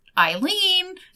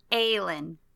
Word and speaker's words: Aelin: I texted you guys and I was like, okay, wait, is Selena like this mysterious Eileen? Is that how you say it? I'm saying it like the Turkish way because it kind of looks like Aelin. – Aelin. See, I keep putting Turkish pronunciations on everything.